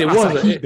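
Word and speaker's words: açaí: I mean it was acai berries. – Ugh. Acai. – I'll get this $11 cup of berries. – A four letter word shouldn't have three syllables.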